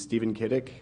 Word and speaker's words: Steven Kiedyk: My name is Stephen Kiedyk, S-T-E-V-E-N-K-I-E-D-Y-K.